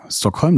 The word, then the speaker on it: Stockholm: Und bei Stockholm ist es so, dass die meisten Ortsnamen im Schwedischen eben auf der ersten Silbe betont werden, weil es zum Beispiel auch bei den ganzen Köpings, also es gibt ja sehr viele Städte, die auf Köping enden, da ist es wichtig, dass man eben unterscheiden kann, ist es jetzt Norrköping oder Linköping oder Söderköping oder was auch immer. Und die Betonung liegt meistens auf der ersten Silbe, deshalb Stockholm.